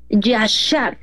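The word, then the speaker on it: yashar (יָשָׁר): Y yo encontré la palabra, el significado de la palabra recto, que se pronuncia yashar.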